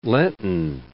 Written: Lenten

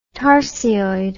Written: tarsioid

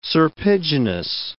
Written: serpiginous

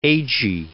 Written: Agee

